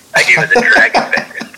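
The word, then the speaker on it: Dragan: Also, I don't mean to correct you, but the only reason I do is because Bender's first name is pronounced Dragon, like Dragon Bender, which sounds like some futuristic Avatar villain shit right there.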